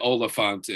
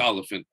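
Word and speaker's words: Olyphant: And he said people say it. Oliphant, and it's Oliphant. I think that's what it is. – Oliphant.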